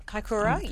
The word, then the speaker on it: Kaikoura: In Kaikoura. I can't pronounce Kaikoura. – Kaikoura, yes.